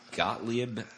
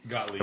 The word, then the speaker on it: Gottlieb: It was established by Sidney Gottlieb. I don't know. I never know how to pronounce that last name, where it's G-O-T-T-L-I-E-B. – Gottlieb. – I believe Gottlieb. – That's Gottlieb.